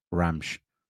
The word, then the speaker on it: Ramos: What I will say is apparently it's pronounced Gonçalo Ramsh.